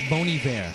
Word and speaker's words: Bon Iver: Yeah, it's pronounced Bon Iver. – How do you pronounce a Bon Iver when it's B-O-N space I-V-E-R? – It's Bon Iver.